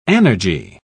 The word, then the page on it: anergy: British English